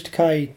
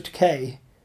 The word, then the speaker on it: Takei: And he'd go, Takei, as in to rhyme with toupee.